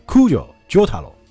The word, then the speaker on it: 空条承太郎: 啊，对对那这个陈太陈太郎读成呢 Kujo 啾太郎，这就是啾啾嘛。